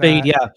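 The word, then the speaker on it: Feyd: I think it's like Fade or something like that. – Fade, yeah.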